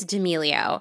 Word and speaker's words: D'Amelio: I think it's D'Amelio.